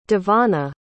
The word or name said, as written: Devana